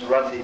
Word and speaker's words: Rati: The conclusion is that bhava bhakti, or Rati, another name for this bhava or ecstasy is called Rati, R-A-T-I, Rati, short A, Rati, is prema, love of God in its budding stage.